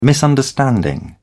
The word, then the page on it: misunderstanding: British English